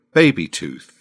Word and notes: baby tooth: British English

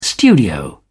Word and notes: studio: British English

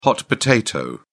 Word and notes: hot potato: British English

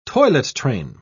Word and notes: toilet-train: British English

